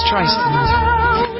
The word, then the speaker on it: Streisand: Streisand.